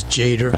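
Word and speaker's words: Tjader: Ronnie Foster's Mystic Brew and Carl Teaters. Is that how you pronounce his name? – I always thought it was Jader. – I says Jader. – Jader, yeah. Okay.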